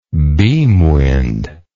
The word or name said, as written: beamwind